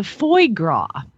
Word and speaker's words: foie gras: Now you skipped over the foie gras. Oh, which F O I E G R A S. – That's foie gras. That's how they pronounce that.